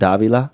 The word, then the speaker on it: DVLA: You pronounce it Davila, but it's spelled DVLA.